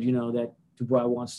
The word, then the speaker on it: Dubois: Dubois.